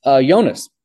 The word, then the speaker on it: Jonas: Is it Jonas or Junas? – Jonas. – Jonas is what he's gone by. Although I think in Finland they do pronounce the J.